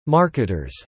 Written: marketers

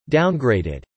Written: downgraded

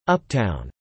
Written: uptown

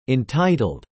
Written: entitled

